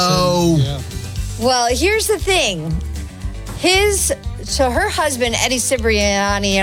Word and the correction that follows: Cibrian: I don't know how you pronounce it, but whatever. Cibrian.